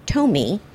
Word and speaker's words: Toume: Interesting tidbit, the name is spelled T-O-U-M-E, and is pronounced Tomi, as it was the chef's nickname growing up because his mother couldn't pronounce Tommy.